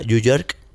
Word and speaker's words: UJ3RK5: Luego me enteré que se pronuncia New York.